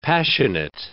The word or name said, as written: passionate